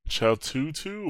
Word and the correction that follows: Cthulhu: Cthulhu. – Yeah, there you go. – Cthulhu.